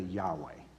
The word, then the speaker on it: Yahweh: We add vowels to it today, and we sang about it today, Yahweh, right? That's how we pronounce the name of God.